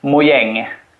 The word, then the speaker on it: Mojang: I usually say Mojang. This was actually something I discussed with the narrator of the audiobook, how to pronounce all the weird Swedish names and words. But I suggested we would say Mojang. But in Swedish, it would be Mojang. – Okay. – Yeah. – Or Mojang, possibly.